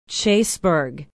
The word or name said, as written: chaseburg